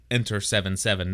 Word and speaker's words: Enter 77: And we are here to discuss and review episode Enter 77, which is actually pronounced Enter 77, not 77, like right. – I've been saying in the past.